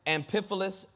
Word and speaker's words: Amphipolis: Starting at the first verse, Paul and Silas then traveled through the towns of Amphipolis, yes, that's how it's pronounced, and Apollonia, and came to Thessalonica, where there was a Jewish synagogue.